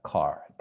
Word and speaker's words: quart: So this one means it's nine, 9 minus a quarter. You see? So how would you say? – Moins le quart. – Okay. So back to the silent letters. Silent. Okay. Silent. So it's almost like car. Car.